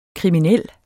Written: kriminel

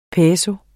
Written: peso

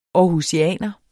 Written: aarhusianer

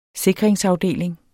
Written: sikringsafdeling

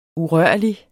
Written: urørlig

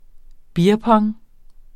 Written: beerpong

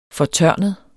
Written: fortørnet

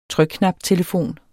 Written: trykknaptelefon

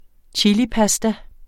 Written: chilipasta

